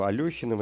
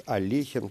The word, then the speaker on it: Алехин: Э, называть его Алехиным, это как-то более более звучно, более созвучно, что ли, к современному русскому языку, тогда как Олехин произносится, наверное, несколько труднее и вообще вызывает вопросы относительно этимологии, да, откуда взялась эта фамилия, кто, кто такие Олехи, а, тогда как с Алехиным все понятно.